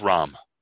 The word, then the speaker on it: Rahm: It's Rahm. – So John Rahm won, but apparently Brooks Koepka choked.